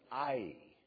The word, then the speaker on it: Ai: The proper pronunciation of it is Ai. A-I. I'm sure here in this church, just as all across Northern Ireland, that has always been called Ai. A Hebrew scholar would have to cringe at that pronunciation.